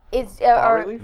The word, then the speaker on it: bas relief: Bas relief? – The S is silent. – Bas relief. – Arc degree. – How do you spell that? – B-A-S.